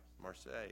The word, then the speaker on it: Marseille: Marseille, yeah, they'll be going there.